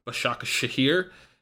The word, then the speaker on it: Başakşehir: Başakşehir.